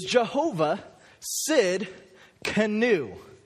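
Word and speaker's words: Jehovah Tsidkenu: Because today our name for God is Jehovah Sid Canu. Yeah. Has a T in it. Kind of throws you off. But it's Jehovah Sid Canu.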